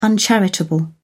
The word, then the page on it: uncharitable: British English